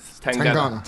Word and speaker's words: Tangana: Am I pronouncing this right? Tangana. – Tangana. – Tangana. – Yeah.